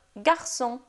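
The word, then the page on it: garçon: language French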